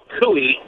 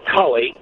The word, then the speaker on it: Cully: a small jazz festival in a town called Cooey. We in America, of course, would pronounce it Cully.